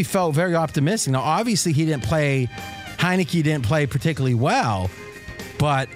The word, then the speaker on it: Heinicke: How do you pronounce his name? – Heinicke. – I was thinking Heineken. And I'm like, it couldn't be Heineken.